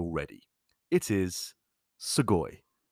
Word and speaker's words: sugoi: That's sugoi. It's spelt S-U-G-O-I, but of course, in Japanese, the letter U is a much softer sound. So instead of something like sugoi, it just comes out as sugoi.